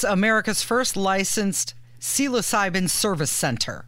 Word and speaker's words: psilocybin: When I asked during the break before we did the segment, I said, how do you say this word? And I started spelling it. I got about three letters in, and he started shouting aggressively at me, psilocybin, psilocybin, psilocybin, idiot.